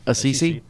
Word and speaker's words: Assisi: I'm saying it wrong, aren't I? – Yeah, Assisi, right.